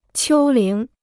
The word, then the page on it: 丘陵: qiū líng